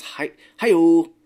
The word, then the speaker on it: Hayao: I was looking up how to pronounce it because I know Miyazaki. I've said that with friends and stuff, but his first name is Hayao.